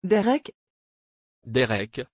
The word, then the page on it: derek: French